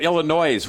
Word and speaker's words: Illinois: So instead, it's Charles from Illinois. Or is it Illinois? What do you say down there? Don't pronounce the S, right?